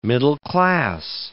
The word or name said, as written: middle-class